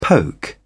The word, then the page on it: poke: British English